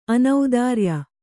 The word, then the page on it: ಅನೌದಾರ್ಯ: anaudārya